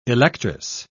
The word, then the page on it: electress: British English